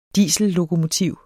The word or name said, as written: diesellokomotiv